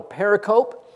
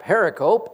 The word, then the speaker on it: pericope: It's called a pericope. I was corrected on that by a friend recently, and he told me it's not pericope, it's pericope. And I go, well, I like pericope.